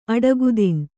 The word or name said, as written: ಅಡಗುತಿನ್